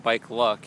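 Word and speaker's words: BikeLoc: Yeah, so we actually, it's spelled Bike L-O-C. We wanted to include the local component in there. And then we pronounce it BikeLuck, as in potluck.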